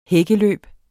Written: hækkeløb

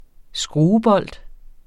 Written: skruebold

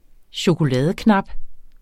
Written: chokoladeknap

